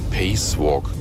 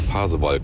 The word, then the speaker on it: Pasewalk: Ich hatte früher mal einen Kollegen, der immer gesagt hat, ich bin durch Pacewalk gefahren. Lange, lange habe ich überlegt, was er damit meinte, bis wir mal drauf kamen. Der Pasewalk.